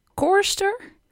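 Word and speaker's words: chorister: Volgens mij spreek je het uit als koorster.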